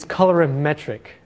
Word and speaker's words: colorimetric: So one is perceptual, and the other, and I always butcher how to pronounce this, is colorimetric. Oh, I think I did it.